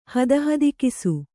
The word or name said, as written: ಹದಹದಿಕಿಸು